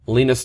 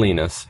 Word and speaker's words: Linus: Now, Linus Torvalds speaks Swedish, so the I is actually pronounced E, so it's Linus.